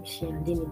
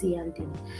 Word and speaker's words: Cialdini: All right, some people call him Cialdini, but the right pronunciation is Cialdini.